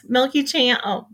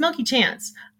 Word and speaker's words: Milky Chance: Milky Chance. I said their name wrong. It's Milky Chance.